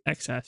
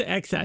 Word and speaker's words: XeSS: It's XS. – MSI claims. – No, it's XS. It's supposed to be like the cool thing. How you say it's XS.